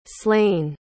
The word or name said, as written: slane